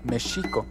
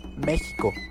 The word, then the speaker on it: México: Los aztecas lo pronunciaban mexico, los españoles lo escribían México, ya que no existía la pronunciación de la J. Cuando cambió la grafía de la X a la J, se le empezó a llamar México con J, pero se siguió escribiendo México con X, lo cual es válido, pues la Real Academia de la Lengua permite excepciones para nombres propios.